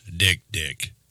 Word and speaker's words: dik-dik: It's called the Kirk's Dick Dick. It's spelled D-I-K-D-I-K.